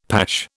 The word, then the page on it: pash: British English